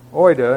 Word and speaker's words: oida: And here in this context, we have the Greek word oida, and again, my apologies to those who are ethnic Greeks here, or Greeks by birth.